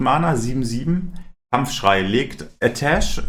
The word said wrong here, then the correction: Atiesh: Nee, wie heißt die Waffe? Ich habe wohl das damals schon nicht aussprechen. – Atiesh.